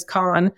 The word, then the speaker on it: Cannes: So the Cannes Lions Festival of Creativity, it's in, I guess the right proper way to say it is Cannes.